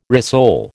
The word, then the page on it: rissole: American English